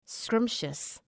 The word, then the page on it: scrumptious: ‘skrʌmpʃəs